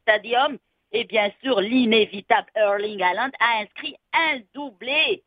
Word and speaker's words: Erling: Erling. – Je prononce bien. – Erling, tu le prononces très bien. Tu le prononces très, très, très bien.